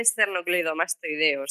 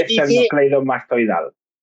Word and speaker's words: esternocleidomastoideo: Y de hecho, se dice esternocleidomastoideo, ¿sabes? O sea, voy a hacer de niña repelente porque lo has dicho mal.